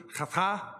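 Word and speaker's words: chata (חטא): We talked about the word sin and what it really means, that both in the Greek and the Hebrew, chata is how you say that, and then in the Greek, harmatia, and it both mean to miss or go wide of the mark.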